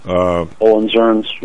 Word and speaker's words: Hohenzollerns: the Hohenzollerns, did I pronounce that right? – Hohenzollerns, yeah.